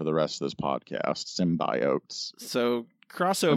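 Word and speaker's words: symbiote: no, no more symbiotes. That's how it's pronounced.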